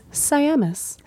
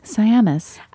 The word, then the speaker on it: Siamis: Like we had said, we got some great notes from Corby Siamis, including that I said her name wrong. And so we just want to encourage that it is Siamis. – Siamis.